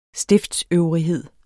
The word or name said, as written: stifter